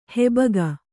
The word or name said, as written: ಹಬ್ಬುಗೆ